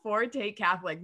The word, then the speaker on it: Forte Catholic: But before we jump into that, Forte Catholic, you want to tell us about them? – You said it right, first of all.